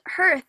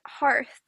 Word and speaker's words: hearth: And I realized that it's not pronounced hearth, even though it's so counterintuitive because it's literally spelled with earth and an H. – Mm-hmm. – It's pronounced hearth.